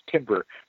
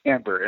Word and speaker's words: timbre: By the way, that's the way that you pronounce that word. Some people, like on the talent shows, pronounce it as timbre, but it's actually timbre, and it means tone color.